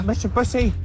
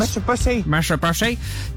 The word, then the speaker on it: Mitsubishi: And then the car behind me, a turquoise Mitsubishi Mirage, or as I pronounced earlier, Mitsubishi. – I've got to call out my own mispronunciation. – Mitsubishi.